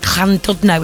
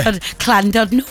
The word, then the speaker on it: Llandudno: And you have got delays as well on Transport for Wales from Hollywood and Llandudno. Is that how you say it? Hasn't it got a clan on the beginning of it? – Oh, he's trying to be all Welsh there. – Llandudno.